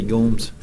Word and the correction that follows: Larry Gomes: oh, I didn't, didn't say that right, but Larry Gomes.